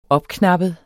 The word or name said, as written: opknappet